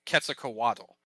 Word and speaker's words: Quetzalcoatl: pronounced Quetzalcoatl.